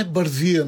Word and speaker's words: Бързия: Ами защото то си се знае Бързия, нали, в нашия. – Да. – Но пък хората се бъркат, казват Бързия, нали.